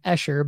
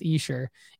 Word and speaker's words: Esher: I was saying Esher, but it's pronounced Esher.